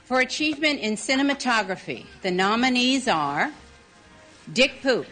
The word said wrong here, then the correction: Dick Pope: Oh, Dick Pope.